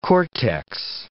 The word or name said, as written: cortex